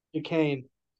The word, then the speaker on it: Duquesne: Duquesne. It's Duquesne. – That doesn't. – So Duquesne, right? – Yeah, that doesn't Duquesne. – phonetically work.